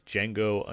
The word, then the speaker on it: Django: My number four is Django Unchained, The D is Silent.